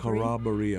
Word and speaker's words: Corroboree: And we'll finish with Corroboree, I think it's called Corroboree. – Corroboree, I believe, is the Earl Brown pronunciation.